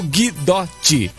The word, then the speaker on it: Guidotti: Rafael Guidotti.